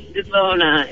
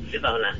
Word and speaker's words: Devona: It's Devona. It's with a long O. It's Devona.